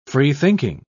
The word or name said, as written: freethinking